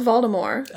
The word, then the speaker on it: Voldemort: hey, it's supposed to be pronounced Voldemort.